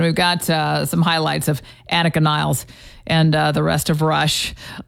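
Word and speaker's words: Annika Nilles: And how do you pronounce her name anyway? It is Annika Niles. According to Alex Lifeson, if you hear Annika pronounce her own name, well, she's German, and it comes out kind of like... So it's hard to grasp. But anyway, Alex Lifeson said Annika Niles, and so that's what we're going with.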